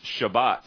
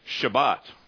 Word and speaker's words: Shabbat: The word Sabbath in Hebrew is pronounced Shabbat. You hear a Jewish person speak of it, especially the Israeli Jews we have on the island here. You'll hear them say Shabbat.